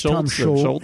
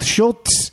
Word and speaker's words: Scholz: I think it's Scholz. Scholz. I think it's Scholz. – All right. – It's not Schultz.